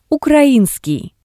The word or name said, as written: ukrainskii